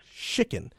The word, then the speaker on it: Chicken: Is it Skicken from Tucson, Arizona? – It's Chicken. – Chicken, oddly spelled.